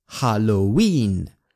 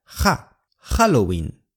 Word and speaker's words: Halloween: Halloween 的英语和西语有区别，虽然 Halloween 这个词来自英语，西语发音会和英语的稍微不一样，英语的重音在 win Halloween Halloween，但西班牙语的重音在 Halloween Halloween，而且在英语发音那个哈很轻轻的哈哈哈，但我们的哈更像哈哈，语式 Halloween 变成 Halloween， Halloween 变成 Halloween。